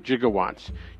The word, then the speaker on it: gigawatts: However, the correct spelling, as everyone has noted online and you can find everywhere, is what I've just mentioned, the G-I-G-A. Although rarely used, the J sound at the beginning of the prefix is an acceptable pronunciation. And every time you hear Christopher Lloyd say gigawatts, your brain thinks J.